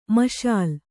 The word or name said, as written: ಮಸಲಾ